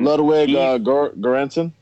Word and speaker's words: Ludwig Göransson: And Ludwig Göransson? – Yes.